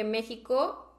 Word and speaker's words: México: Entonces me decidí por jarabe, porque México se pronuncia como si fuera con J, pero de verdad es con X.